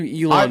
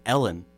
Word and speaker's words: Elon: I've never heard the name Elon before. – Yeah, I have. – Wait, what if we're just pronouncing it wrong and it's Ellen? – It's not. No. No, it's Elon.